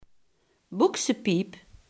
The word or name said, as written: broekspijp